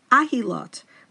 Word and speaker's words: Ahilot: And some even suggest that the name of this Masechet should be pronounced Ahilot, which means covering, instead of Ohalot, which means tents.